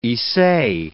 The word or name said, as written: issei